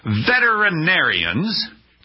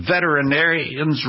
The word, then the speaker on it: veterinarians: Veterinarians, do you notice I pronounce veterinarians right, correctly? Most people say veterinarians. They're not. They're not veterinarians. Now, pumpkins are pumpkins and possums are possums, but these are veterinarians. It's got to get all the syllables in there.